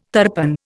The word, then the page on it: tarpan: Indian